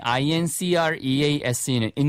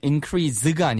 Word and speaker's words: increase: 동사이지만 발음이 increase는 increase가 아니고 increase라는 거 주의하세요. – Reading books would increase your knowledge. – 네, 명사할 때는 increase가 되겠죠.